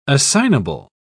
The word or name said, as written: assignable